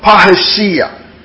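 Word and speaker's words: parrhesia: Now that word boldness is a Greek word, parhesia. Or parhesioma. I'll spell it for you. P-A-R-R-H-E-S-I-A. P-A-R-R-H-E-S-I-A. Or P-A-R-R-H-E-S-I-A-Z-O-M-A-I.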